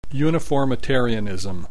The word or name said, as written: uniformitarianism